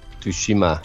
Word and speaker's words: Tsushima: All right, so Sony will most likely talk about The Last of Us Part 2, Death Stranding, and Ghost of Tsushima. I actually said that without butchering the shit out. – Tsushima.